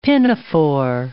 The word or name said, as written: pinafore